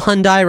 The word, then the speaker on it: Hyundai: No, well, Hyundai rhymes with hung guy, so yeah.